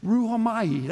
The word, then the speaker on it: rhuomai: It's ruhomai. That's the word itself. It's spelled R-H-U-O-M-A-I.